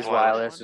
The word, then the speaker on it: Zweilous: I did my research about how the lures only attracted the, how do you say it, Zweilous? It's the second evolution of it. – I say Zweilous as well.